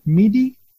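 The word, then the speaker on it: M'hidi: I had heard about Larbi bin, is it Mhidi? How do you say yes.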